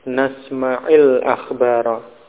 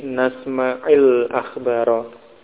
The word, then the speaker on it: nasma'il akhbara: Ijlis nasma' al-akhbara, atau kalau bersambung nasma'il akhbara. Ya, untuk menghindari bertemunya dua sukun, dua huruf yang sukun, ya, nasma' al-akhbara menjadi nasma'il akhbara.